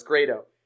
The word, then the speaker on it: Grado: and he told me it's pronounced not Grado, it's Grado.